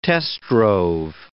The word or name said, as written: test-drove